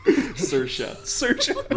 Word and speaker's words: Saoirse: Saoirse. Rhymes with inertia.